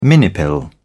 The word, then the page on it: minipill: British English